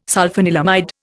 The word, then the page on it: sulphanilamide: Indian